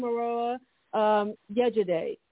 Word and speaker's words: Morowa Yejidé: Good morning, Morowa Yejidé.